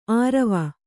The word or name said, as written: ಆರವ